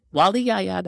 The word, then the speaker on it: Waliyaya: I have to say, no, it's Nyamekye. Nope, Waliyaya. That's how you say it.